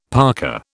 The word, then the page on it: parka: British English